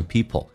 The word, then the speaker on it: people: 好，来看一下第一个 some people，美式发音当中 p 跟 l 趋向于发 b 跟 l，如果加速慢速还可以发成 people，但是这个 l 一定要发出来，舌尖抬起来靠向你的上齿龈，叫 all，上牙齿后背一点 all people。